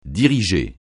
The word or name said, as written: diriger